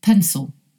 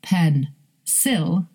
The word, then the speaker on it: pencil: The stress is on the first syllable and the second syllable is unstressed and that vowel is replaced by the schwa sound. So we don't say pen, sil, we say pencil.